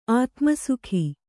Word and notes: ಆತುಮಸುಖಿ: ātumasukhi

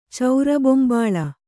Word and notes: ಚೌರಬೊಂಬಾಳ: Kannada

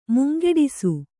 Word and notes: ಮುಂಗೆಡಿಸು: mungeḍisu; kannada

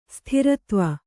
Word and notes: ಸ್ತ್ರೀತ್ವ: strītva

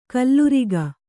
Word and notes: ಖಲೂರಿಕೆ: khalūrike

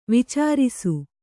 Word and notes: ವಿಚಾರಿಸು: vicārisu

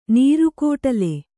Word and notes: ನೀರುಕೋಟಲೆ: nīru kōṭale

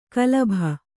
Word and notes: ಕಲುಬಿ: Kannada

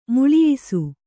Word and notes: ಮೇಳಯಿಸು: mēḷayisu

